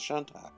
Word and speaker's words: Shantak: Nah, David was pretty much on the money. It's a Shantak.